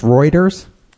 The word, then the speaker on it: Reuters: It's really pronounced Reuters.